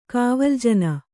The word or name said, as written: ಕಾವಲ್ಜನ